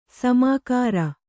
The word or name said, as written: ಸಮಗಾರ